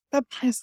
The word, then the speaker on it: Papanos: I'm going to think about how you told me to say it. Papanos. Did I do it? – Papanos.